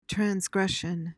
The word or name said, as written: transgression